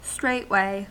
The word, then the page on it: straightway: American English